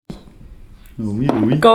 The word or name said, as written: oh oui